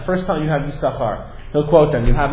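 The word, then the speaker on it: Yissachar: And all the Chachmei Masora of the Rishonim and Achronim are basically Sebe Pe'echa, that you follow, you read it Yis-Sachar, one sin is silent, it's a Kreuk sin.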